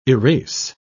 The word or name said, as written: erase